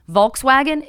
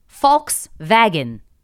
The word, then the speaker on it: Volkswagen: But one of the things was Volkswagen is actually Volkswagen, because obviously it's not an English American company.